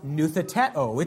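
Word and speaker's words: nuthetēo (νουθετέω): The Greek word here is pronounced nuthetēo. It's where we get our English term, nuthetic counseling.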